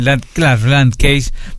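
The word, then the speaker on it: Landkäse: ¿cómo se pronuncia? Porque es un nombre en alemán y quiere decir queso de campo. – Queso de campo, muy bien. Landcase, perfecto. Eric, sos representante de la Asociación de Quesos Artesanales, del queso artesanal, de, en este caso, participando en este esquema.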